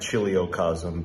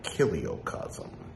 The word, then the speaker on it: chiliocosm: Usually when it comes to Greek-derived words that start with CH, as in chrome, C-H-R-O-M-E, the C-H is pronounced K. So it looks like chiliocosm, but it's probably more like kiliocosm.